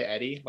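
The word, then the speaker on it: Hedi: People say Eddie, like just drop the H completely.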